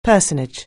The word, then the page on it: personage: anglais